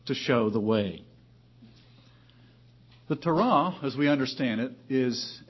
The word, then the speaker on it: Torah: Some pronounce it Torah. Most lexicons put the syllable on the accent on the second syllable, Torah.